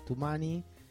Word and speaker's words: Toumani: Nosotros nos vamos a meter ahora con un músico, hay que ponerse de pie en este caso, es Toumani Diabaté, Toumani, pronunciado Toumani, se escribe Toumani,